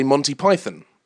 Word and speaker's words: Monty Python: And a British person like myself would say Monty Python. Americans tend to put the emphasis on the O, particularly on Python, because you said Python.